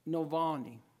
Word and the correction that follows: Navalny: I hope I'm pronouncing his last name right. It's N A V A L N Y, Navalny.